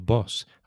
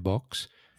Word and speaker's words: boss: In fact, I can remember like when I first arrived here in Spain, I noticed a few people actually pronounced that word incorrectly, boss, and they had a more of an X sound.